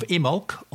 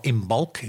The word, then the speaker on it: Imbolc: And going even further ahead to next February, when it is the next celebration of Imolc, or Imbolc, if you like to pronounce the B in it.